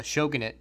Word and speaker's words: shogunate: which led to the Tokugawa shogunate being created. – Oh, shogunate is how you pronounce it.